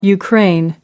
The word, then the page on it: ukraine: American English